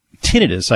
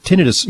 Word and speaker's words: tinnitus: So when it comes to tinnitus, I think is the correct pronunciation. – Okay. Thank you. – When it comes to tinnitus, you know, let's talk first from a dietary standpoint.